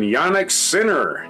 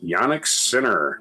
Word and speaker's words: Jannik Sinner: In the Australian Open, Jannik Sinner. That's how his name's pronounced. That's how it's spelled, so that's how I'm going to pronounce it. Jannik Sinner.